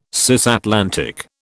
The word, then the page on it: cisatlantic: British English